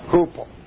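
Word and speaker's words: hupo (ὑπό): Now there's a Greek preposition which means under, and that's hupo. That's not the way it looks in Greek, but that's the way it's pronounced. Hupo.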